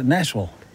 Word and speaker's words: Nashville: Yeah, Nashville. – You know, that's actually closer to how a local would say it. Anything that has a vill at the end, they say vull, like Louisville, Kentucky. It looks like Louisville or Louisville, but Louisville.